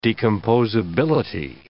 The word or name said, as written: decomposability